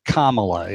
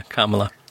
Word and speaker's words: Kamala: Someone apparently has written a parody of Camelot with her name because it is supposed to be Kamala, isn't it? – Yeah, Kamala.